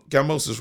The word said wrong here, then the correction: Kambosos: right? – Yeah, Kambosos Jr. Kambosos Jr. This is a mad fight, bro.